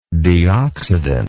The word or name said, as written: deoxidant